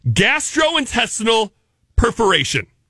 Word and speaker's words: gastrointestinal perforation: Gastrointestinal perforation. – Oh my gosh, I think you said that right.